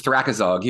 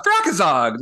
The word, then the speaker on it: Thrakazog: and he's like thorax in a bog, Thrakazog, like suitcase in a log, Thrakazog.